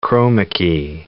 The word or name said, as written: chroma key